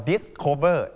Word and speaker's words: discover: discover นะครับตัว s นี้จะทำให้เสียงเกกลายเป็นเสียงเกเหมือนคำว่า cool